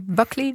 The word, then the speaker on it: Buckley: Ook onder je leiding van, dan moet ik het even goed uitspreken, Jules Brooklyn? – Ja, Buckley, denk ik.